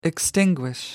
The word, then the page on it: extinguish: American English